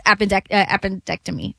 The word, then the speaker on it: appendectomy: We can't say everything's changed just because they beat the Celtics in a game seven after, you know, his appendectomy.